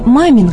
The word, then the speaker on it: Мамин: Фамилия Мамин когда-то произносилась, очевидно, с ударением на последнем слоге «Мамин», поскольку происходит от татарского имени «Мамин» или башкирского «Маминь».